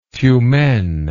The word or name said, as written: tyumen